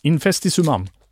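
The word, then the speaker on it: Infestissumam: Infestisumam tror jag det uttalas.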